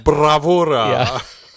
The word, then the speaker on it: bravura: You should roll your R's. – Bravura.